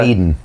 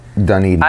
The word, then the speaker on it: Dunedin: Dunedin. – Dunedin? Is that how you pronounce it? – Dunedin.